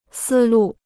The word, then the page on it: 思路: sī lù